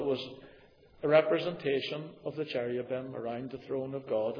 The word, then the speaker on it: cherubim: You read about cherubs, cherubim, we pronounce it, were put at the entrance to the gate to the garden to keep man out.